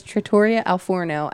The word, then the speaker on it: Trattoria Al Forno: So he doesn't know how to pronounce it right. – No, but I just do it for fun at this point. – Okay. So it's Trattoria Al Forno at the Disney's Boardwalk Resort.